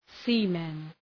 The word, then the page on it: seamen: ‘si:mən